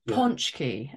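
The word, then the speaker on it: pączki: I call it Donut Day, but it's called Ponchki. And so you spell it with a P, A, but it's not an A, it's an OW, which is an A with a little flick underneath.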